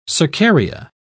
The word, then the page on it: cercaria: British English